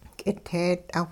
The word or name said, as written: k'iteet'aw